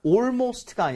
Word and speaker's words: almost: Almost가 아니라 AL. 어떻게 발음했죠? – 좀 벌려줘야지, 이번 발음. – 아, almost. Almost. 자, AL. 말고 ALL도 마찬가지예요. 발음은 어차피 우리가 all 이런 식으로 돼 있는 거잖아요. 그런 스펠링이 나오면 웬만하면 all에 가깝게 발음하는 게 좋습니다.